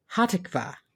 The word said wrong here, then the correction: Hatikva: Close. Hatikva. We stress the second syllable. – I put the accent on the wrong syllable.